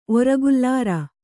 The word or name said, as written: ಒರಗುಲ್ಲಾರ